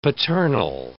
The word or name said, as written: paternal